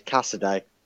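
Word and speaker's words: Casadei: But Kasidri and Fatawa. – Cassidy. I will teach you to say his name right. It's Cassidy.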